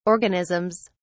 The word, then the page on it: organisms: British English